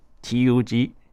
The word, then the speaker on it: Tug: 那突厥的军乐史，我们可以溯源到他们有几个词哦，就是一个是 T U G，然后它是 Tug，然后就其实就是像突厥的它的发音，然后还有另外一个词是 N E V B E T，Nevbet，然后后面还有梅特尔这个词。